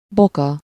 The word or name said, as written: cheville